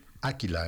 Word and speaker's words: Áquila: Áquila, en realidad Áquila. Es Áquila. – Es una palabra esdrújula. – Lleva acento en la A, sí, es Áquila.